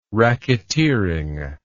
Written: racketeering

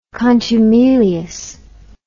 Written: contumeliousness

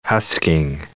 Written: husking

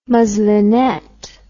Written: muslinet

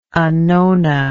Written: annona

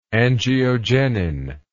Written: angiogenin

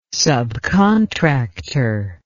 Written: subcontractor

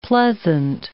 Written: pleasant

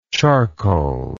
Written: charcoaly

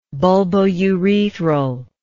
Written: bulbourethral

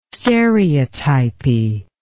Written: stereotypy

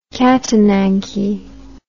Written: catananche

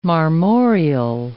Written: marmoreal

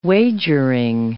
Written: wagering

